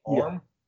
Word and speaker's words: Orm: But I'm loving the look of King Orm. I'm saying it right, Orm, right? Orm? – Yeah.